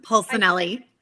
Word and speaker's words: Polsinelli: Did I say that right? – Polsinelli. – Polsinelli. I totally messed it up and we just talked about it.